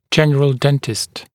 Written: general dentist

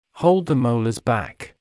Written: hold the molars back

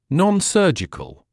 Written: non-surgical

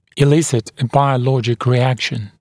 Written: elicit a biologic reaction

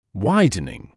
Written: widening

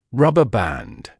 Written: rubber band

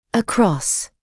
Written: across

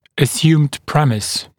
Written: assumed premise

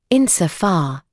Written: insofar

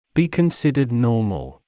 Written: be considered normal

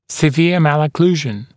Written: severe malocclusion

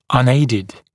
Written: unaided

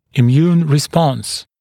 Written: immune response

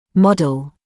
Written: model

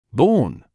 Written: born

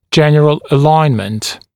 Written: general alignment

